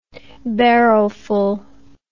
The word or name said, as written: barrowful